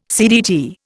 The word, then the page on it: CDT: Indian